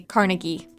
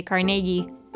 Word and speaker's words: Carnegie: Everywhere else I've lived, they say Carnegie, but here they say Carnegie, with a bigger emphasis on the middle syllable.